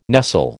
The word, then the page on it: nestle: American English